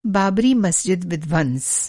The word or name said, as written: विध्वंस